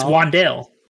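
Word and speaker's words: Wan'Dale: Never actually heard that name pronounced. Is it Wandale? Is it Wandale? – No, it's Wandale. – Wandale? All right. – Wandale Robinson, yeah.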